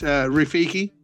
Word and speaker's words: Rafiki: It's Rafiki. – Hey, just type how you spell it. – It's R-A-F-I-K-I.